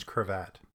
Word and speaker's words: Krevat: Yep, it rhymes. Cravat. Matt Cravat. I mean, but it's not Matt, but if it was Matt, it would rhyme and it would be Matt Cravat.